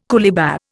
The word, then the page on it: coolibah: Indian